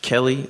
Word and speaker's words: Kelley: Kelley. – Yeah. – And Kelley is spelled with K-E-L-L-E-Y.